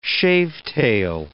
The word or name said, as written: shavetail